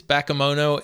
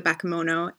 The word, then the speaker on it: Bakemono: I think it's Bakemono. It's a Japanese term for a type of supernatural creature.